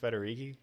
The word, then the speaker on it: Federighi: Are you only doing the Italian thing because my last name's Federighi? – I had no idea your last name was Federici. Did I say it right? It's Federici. I feel like you I said did. It right. – That's impressive.